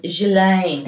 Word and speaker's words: Ghislaine: Ghislaine. – Ghislaine.